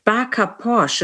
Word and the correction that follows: Bacha Posh: is it? Baka Posh? – A Bacha Posh. – Bacha Posh.